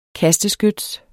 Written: kasteskyts

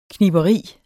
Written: kniberi

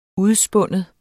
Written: udspinde

